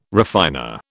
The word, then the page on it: refiner: British English